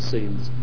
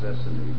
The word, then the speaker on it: Essene: This is where the Essenes would isolate themselves. – Been on that, I thought it was Essene, but you say Essene? – Yeah, some pronounce it one, some pronounce it the other, yeah.